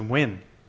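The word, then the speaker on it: Nguyen: I learned how to pronounce it. It's Wynn. – It's Wynn? N-G-U-Y.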